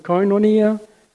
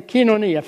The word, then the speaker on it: koinonia: Lots of people pronounce it koinonia. That's the Latinized way of pronouncing the word. I've never heard a Greek say koinonia. I've always heard Greeks say koinonia. So I'm going to say koinonia, fellowship, which means it is communion.